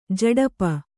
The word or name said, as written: ಝಡಪ